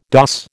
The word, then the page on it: doss: American English